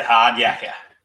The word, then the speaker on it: hard yakka: The hard yakka. Did I say that right? – That's it, hard yakka.